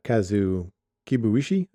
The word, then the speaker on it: Kazu Kibuishi: kazu kibuishi i think is how you pronounce yes, it sorry thank you